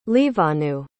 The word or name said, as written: liwanu